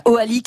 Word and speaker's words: Hoali: Voilà, Oali qui s'écrit H-O-A-L-I.